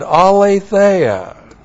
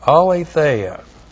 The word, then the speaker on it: aletheia: Grace righteous production, Royal Family Honor Code, and aletheia. A-L long E-T-H-E-I-A. Aletheia.